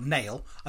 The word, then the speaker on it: Nail: It's signed by a priest, cleric, called Abbé Nail. N-A-I-L. Nail. I don't know how you pronounce that in French. – N-A-I-L.